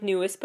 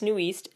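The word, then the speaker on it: NU'EST: New East, spelled that it looks like newest, but it's New East, N-U apostrophe E-S-T, stands for New Established Style and Tempo.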